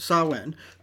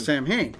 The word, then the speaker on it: Samhain: And you know, it's really interesting too, because you had pronounced Samhain the way that you pronounce it in Scotland. – Yeah. – And it's so different in those British Isles, how differently it's pronounced. And of course, America always pronounces it Samhain.